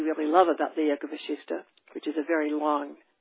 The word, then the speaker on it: Vasishtha: It's called the Concise Yoga Vasishtha, spelled V-A-S-I-S-T-H-A or V-A-S-I-S-H-T-H-A, because the second sha in Vasishtha is pronounced like S-H, so it's sometimes spelled with an S-H.